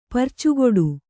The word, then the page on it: ಪರ್ಚುಗುಡು: parcuguḍu